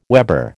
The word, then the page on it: weber: American English